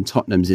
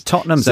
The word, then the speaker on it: Tottenham: Tottenham's an interesting one, I think, especially for English learners, because that's always pronounced like Tottenham. But anyone in England will always just say Tottenham. Like, there's lots of letters there which we just completely forget about.